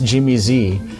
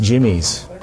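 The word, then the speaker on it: Jimmy'Z: And I learned later that it was never pronounced Jimmy Z. It was pronounced Jimmy's. They just used a Z instead of an S. And it was an apostrophe, right? Like Jimmy's. – Oh. – It was possessive.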